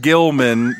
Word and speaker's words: Gilman: Look, I'll just tell you, I named him Gilman because it rhymes with kill, and he was gonna get killed.